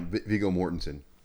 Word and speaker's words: Viggo Mortensen: Viggo Mortensen? I can't remember how you say it. – Yeah, Viggo Mortensen.